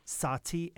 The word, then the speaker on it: Sahti: If you can't hear the distinction in that pronunciation, the style name is Sahti, S-A-H-T-I. Your beer is Sahti, S-A-H-A-T-I.